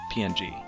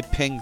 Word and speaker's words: PNG: Now, see, is it a ping or a PNG? Would you say the full-on... – I say ping, so I don't have to say three syllables.